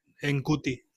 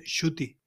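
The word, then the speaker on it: Ncuti: Que no se dice en cuti, se dice Shuti, aunque se escriba NC, es alucinante.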